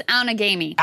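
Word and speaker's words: Outagamie: I'm going to butcher the name. I'm sorry, Appleton, Wisconsin. Autogamy. – I think it's Outagamy.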